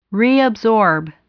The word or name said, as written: reabsorb